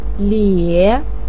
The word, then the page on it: lie3: pinyin